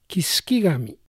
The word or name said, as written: Kisukigami